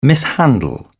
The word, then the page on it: mishandle: British English